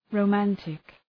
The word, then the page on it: romantic: rəʋ’mæntık